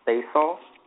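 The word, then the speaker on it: Stacil: Basil. It's like, well, I have to explain how to pronounce it because not a lot of people know it. It's like Stacy and pencil in a way.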